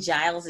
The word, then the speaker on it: Giles: And Deidre is spelled D-E-T-H-R-A and Giles is G-I-L-E-S.